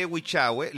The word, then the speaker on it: Huichagüe: Ahora bien, cuando ustedes hablen de Huichagüe, les voy a pedir que cuando pronuncien Huichagüe lo digan como, ojalá como un tono cuico, como Huichagüe.